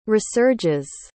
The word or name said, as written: resurges